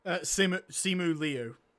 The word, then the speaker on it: Simu Liu: Simu Liu? – Simu Liu.